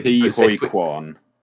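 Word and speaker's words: Ke Huy Quan: Ki-Hui Kwan.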